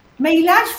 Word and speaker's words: Meilach: Meilach fue mi padre. – Ajá. – En realidad se lo llamaba Mauricio. Meilach es el nombre que está, la CH se pronuncia como J.